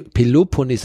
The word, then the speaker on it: Peloponnes: über Patras sind wir dann nach äh, auf, in die, auf die Penel, äh, Peloponnes. – Penepol. Pele Peloponnes. Das konnte ich da schon da nicht aussprechen. Jetzt zum späten Abend wird es dann auch noch ein bisschen schwieriger. Aber wir sind dann östlich gefahren, also wir sind dann erstmal ähm, Peloponnes. Genau, richtig, genau.